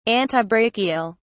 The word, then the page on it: antibrachial: British English